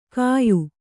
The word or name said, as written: ಕಯ್